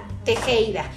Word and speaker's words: Tejeda: Me van a encontrar con mi nombre, Melisa Tejeda.